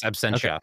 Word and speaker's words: Absentia: You know his work from Absentia. Am I pronouncing that right? – Yes, Absentia.